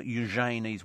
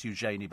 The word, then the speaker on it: Eugenie: Sally wants to know if we're paying for Eugenie's wedding. Oh, it's not pronounced Eugenie, by the way. It's Eugenie.